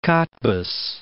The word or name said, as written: Kottbus